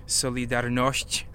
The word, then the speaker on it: Solidarność: I think it's called, that's how you say it in Polish, Solidarność, Solidarity Movement, under Lech Wałęsa.